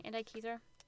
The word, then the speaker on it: Antikythera: So I chose the, you know, I really should look up the name of this and how to pronounce it, but I think it's pronounced the Antikythera mechanism. – Oh, I can't see. Yeah, that looks correct. Antikythera.